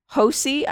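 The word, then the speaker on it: Hosea: Moody and Bristol County District Attorney Hosey, I believe is how you pronounce it. I saw a different pronunciations for this, but it's H-O-S-E-A.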